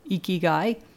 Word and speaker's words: Ikigai: Ikigai.